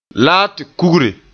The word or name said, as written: kugre